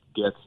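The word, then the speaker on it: Gaetz: And I hope Congressman, I think it's Getz is how you pronounce his name, I hope he follows through on looking into that for purposes of investigation.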